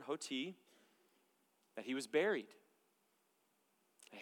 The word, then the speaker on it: hoti: It's a Greek word, hoti, H-O-T-I. That is how you say it.